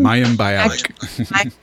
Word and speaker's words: Mayim Bialik: There's just some Mayim Bialik.